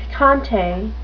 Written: picante

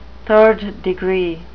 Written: third degree

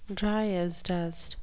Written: dryasdust

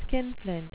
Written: skinflint

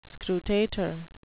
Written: scrutator

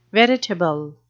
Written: veritable

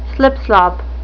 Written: slipslop

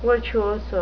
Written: virtuoso

